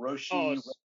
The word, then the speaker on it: Roche: I'm not certain how you pronounce his last name. Oh, Roshi.